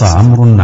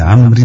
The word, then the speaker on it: عمرو: إن هذه الواو التي تلحق كلمة عمرو في الرفع والجر لا ننطقها في القراءة، فهي حرف زائد يكتب ولا ينطق.